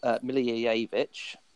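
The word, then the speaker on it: Milivojević: So I went for Milijevic, which is how you pronounce it, and he did really well this week.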